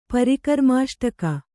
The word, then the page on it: ಪರಿಕರ್ಮಾಷ್ಟಕ: parikarrmāṣṭaka